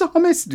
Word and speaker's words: James: Çünkü e, sağ olsun bir kanalda e, hep James Rodriguez dendiği için e, TRT spikerleri zavallılar işte yok Kolombiyalı meslektaşlarımıza sorduk. Başka İspanyolca bilen meslektaşlarımıza sorduk. Kolombiya taraftarına sorduk. Hepsi Hames diyor diyerekten defalarca e, maç anlatımlarını bırakıp James Rodriguez'in adı nasıl okunacak tartışmasını yaşadık.